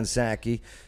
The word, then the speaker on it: Psaki: White House press secretary Jen Psaki, and plus she has a silent P in her last name.